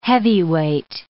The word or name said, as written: heavyweight